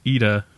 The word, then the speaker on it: Ida: film Ida, pronounced Ida for anyone who actually cares.